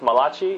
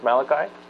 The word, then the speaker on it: Malachi: It is not pronounced Malachi, it is Malachi.